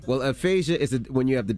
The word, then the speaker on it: aphasia: Spell it to the people. – All right. – A-P-H-A-S-I-A. – Yep. Aphasia. – Aphasia. I think it's aphasia.